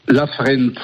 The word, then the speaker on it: Lafrenz: Oye, ¿cómo se pronuncia bien el nombre? Traute Lafrenz, ¿o cómo se pronuncia? – Lafrenz.